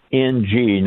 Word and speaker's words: Ng: Just so everybody knows, it's NG, not ING for you. Ng is NG, is how you pronounce or how you publish his name.